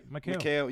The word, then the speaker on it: Mikal: I had him in my top three, right next to Marcus Smart and Michael Bridges, Mikal Bridges. How you pronounce it? Mikal. – You say it right, Mikal.